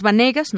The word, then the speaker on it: Vanegas: Eh, es Vanegas, no Venegas como lo pronuncié, así que pues bueno, hago esta corrección.